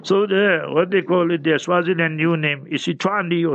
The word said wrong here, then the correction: Eswatini: Dawood Mondi says Eswatini. – Yeah, Eswatini. You see, he knows he can pronounce the word properly with tajweed in it. Eswatini. You must pull it a little bit, Mondi.